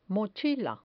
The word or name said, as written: mochila